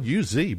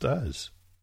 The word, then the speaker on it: Uz: Now, it's spelled U-Z, but it's pronounced Uz, like you and I, Uz.